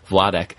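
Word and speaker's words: Władek: So his father's name would be Vladek. – All right. – Like V-W-A-D-E-K. – Yes, it's spelled V-L-A, but we should pronounce it as a V-W.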